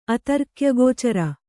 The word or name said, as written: ಅತರ್ಕ್ಯಗೋಚರ